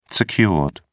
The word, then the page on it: secured: British English